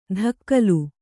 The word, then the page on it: ದಕ್ಕಲು: Kannada